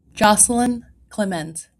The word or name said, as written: Jocelyn Climent